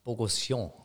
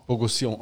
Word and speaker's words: Bogosion: Kannst du mir sagen, wie er ausgesprochen wird? Ich, ich sehe nur, ich habe es jetzt. – Bogosion. – Bogosion, ah, eh, das hätte ich nicht hinbekommen. Äh, französisch.